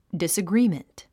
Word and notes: disagreement: dìsəɡríːmənt; ディッサグリィメント

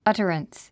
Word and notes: utterance: ʌ́tərəns; アタランス

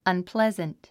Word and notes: unpleasant: ʌnplézənt; アンプレザント

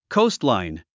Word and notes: coastline: kóustlàin; コウストライン

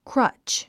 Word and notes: crutch: krʌ́tʃ; クラチ

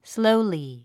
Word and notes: slowly: slóuli; スロゥリィ

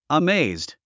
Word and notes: amazed: əméizd; アメェイズド

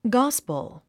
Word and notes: gospel: ɡάspəl; ガァスポォ

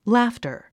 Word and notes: laughter: lǽftə r; ラフタァ

